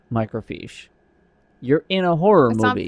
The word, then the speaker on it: microfiche: But if you're looking at microfiche, then... – Fish. – That's how it's pronounced.